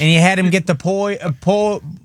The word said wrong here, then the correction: po' boy: How do you say it? – Fried. – It's a po' boy.